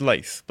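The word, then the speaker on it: Lathe: Le Guin, and I recommend reading The Laugh of Heaven if you haven't. And I know John's already going to complain at me how I mispronounced that. Apparently it's Laith, but I'm not posh enough.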